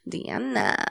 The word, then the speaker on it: Deanna: Deanna.